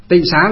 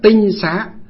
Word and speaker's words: tịnh xá: Tịnh xá là chỉ cho là nó gọi, bây giờ ngày xưa nguyên âm của nó gọi là tinh xá, nhưng bây giờ chúng ta đọc là tịnh xá.